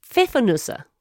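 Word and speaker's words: Pfeffernüsse: You call them pfiffernuss, I think. – Pfiffernuss, that's what I thought. – Well, I would take that. – But what? How did you say it? Can you say it one more time? – Pfiffernusse, I think. – Pfiffernusse. – That's the plural, I think.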